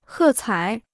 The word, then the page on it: 喝彩: hè cǎi